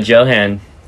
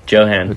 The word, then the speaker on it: Johan: Did you pronounce that right? – Johan. Johan. – Okay. I've been saying Johan forever. – Yeah, me too until today. – Oh, nice. Yeah. Well, you got it now. Most people say it wrong anyway. Like, I think most of my friends say it wrong still.